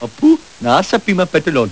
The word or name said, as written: Apu Nahasapeemapetilon